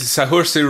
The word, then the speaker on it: Saoirse: It's Saoirse Ronan.